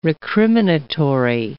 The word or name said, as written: recriminatory